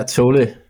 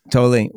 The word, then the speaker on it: Tolle: Yeah, Tolle. – Tolle.